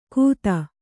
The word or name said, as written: ಖುದಾ